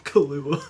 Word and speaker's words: Killua: Killua. – Yeah, like the alcohol.